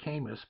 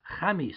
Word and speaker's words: Khamis: They pronounce it Camus, but obviously we're talking the Arabic language, Khamis, K-H-A-M-I-S.